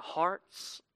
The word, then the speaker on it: Harz: And one day he and his wife went for a walk in the, if I'm saying this right, the Harz Mountains, H-A-R-Z. Yeah, Randy says I'm saying it right, so Randy, smartest person in the room, that's right, Harz Mountains.